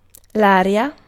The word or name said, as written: wind